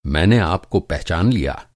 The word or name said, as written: पहचानना